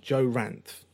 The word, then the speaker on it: Ranft: Yeah, uh, sorry, Joe. Joe Ranft. So it's uh, R A N F T.